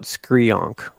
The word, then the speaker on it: Skareeonk: So usually in the comic books, it's spelled out Skrionk, which is like S-K-A-R-E-E-O-N-K.